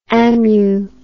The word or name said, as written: amu